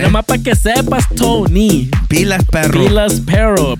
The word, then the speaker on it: Toeknee: Before we get into it, make sure you guys go follow him on Instagram at DJ Tony. That's DJ T O E K N E E. The K is silent.